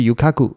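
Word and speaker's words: 遊廓: 那发音是 Yukaku，这里的每间油库都是敞开着店门，门口都会挂着一个黄色的灯笼哦。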